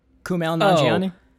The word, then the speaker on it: Kumail Nanjiani: Kumail Nanjiani? – Yes, thank you.